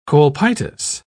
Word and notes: colpitis: British English

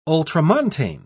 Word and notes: ultramontane: British English

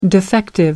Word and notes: defective: American English

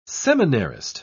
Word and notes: seminarist: British English

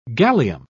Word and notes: gallium: British English

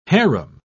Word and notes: hareem: British English

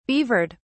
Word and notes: beavered: British English

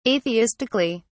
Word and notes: atheistically: British English

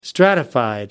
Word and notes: stratified: British English

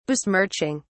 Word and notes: besmirching: British English

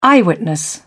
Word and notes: eyewitness: British English

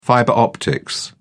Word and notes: fiber optics: British English

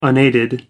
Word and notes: unaided: American English

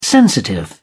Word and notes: sensitive: British English

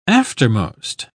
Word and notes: aftermost: British English

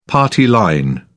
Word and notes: party line: British English

